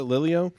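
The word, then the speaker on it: Lelio: I think that's how you pronounce it, Lillo.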